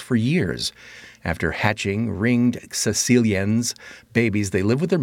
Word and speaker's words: Caecilians: They got a pronunciation here. Caecilians. That's how you pronounce it. Caecilians.